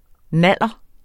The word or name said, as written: naller